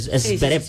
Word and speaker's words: Zverev: Siempre lo digo mal. Zverev. Sí. Corrección ahí.